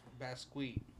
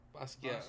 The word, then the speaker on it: Basquiat: Bad squeak. – Bad squeak. Hey, right? So Basquiat. I think you're saying it wrong, dog. – Basquiat.